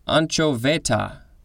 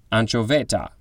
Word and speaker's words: anchoveta: Next we have, I think it's pronounced, anchoveta, A-N-C-H-O-V-E-T-A.